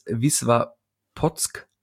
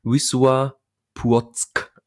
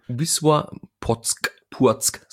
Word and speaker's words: Wisła Płock: Ich hatte es als Wiswa Potsk lautmalerisch aufgeschrieben. Das heißt aber wohl Wisła Płock. Also dieses L wird nicht als L ausgesprochen.